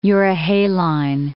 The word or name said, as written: euryhaline